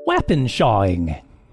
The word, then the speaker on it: wappenschawing: Wappenshawing.